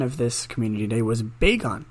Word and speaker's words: Bagon: Uh, the correct pronunciation is Bagon, according to the Pokemon community.